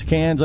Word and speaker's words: Cairns: By the way, it's Cairns, I say. Once again, they pronounce it Cairns, but it's spelled Karen.